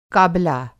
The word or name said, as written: काबला